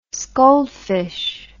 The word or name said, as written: scaldfish